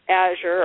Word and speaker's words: Azure: And I now there's controversy on how to say that name, so I'll spell it for you. It's A-Z-U-R-E, Azure or Azure Standard.